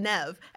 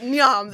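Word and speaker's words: Niamh: And the same thing with Nev. There's no V in it. There is no V in Nev. And I remember reading a book where the character's middle name was Nev, and it's spelled N-I-A-H-M. That's Nyam.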